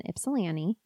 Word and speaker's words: Ypsilanti: So then he went into treatment at the Michigan Center for Forensic Psychiatry in Ypsilanti. Yes, that's how you say it. If anybody sees it written, they're going to be like, she didn't say that right. you have to be from here to know.